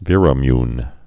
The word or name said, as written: Viramune